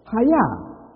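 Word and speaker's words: haya (חיה): Now, the word wild animals in our verse here actually comes from a Hebrew word, haya. H-A-Y-A. And some people spell it as C-H-A-Y.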